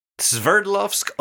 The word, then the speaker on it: Sverdlovsk: Sverdlovsk Oblast.